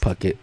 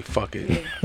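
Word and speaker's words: Phuket: Puck It. – Puck It? – No. – Nah, it's definitely Fuck It. – Yeah. – Oh, so it's P-H-U-K-E-T.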